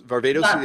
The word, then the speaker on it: Varvatos: Varvatos.